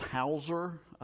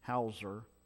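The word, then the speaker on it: Heuser: And the last name, I think is pronounced Hauser, but it's spelled different because he's German. It's H-E-U-S-E-R, Hauser.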